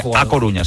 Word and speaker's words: A Coruña: Se calienta mucho el tema de Adama Traoré por el Deportivo de La Coruña. Este fichaje puede despertar muchas ilusiones en el Deportivo de A Coruña. – Ay, ay. – No lo he dicho bien. A Coruña.